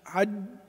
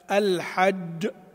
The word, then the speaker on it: الحج: بعضهم يقول الحج حج كأننا ننطق دال أولا، وفج فج فج، لا، الحج الحج، وفجرنا ثجاجا ثجاجا.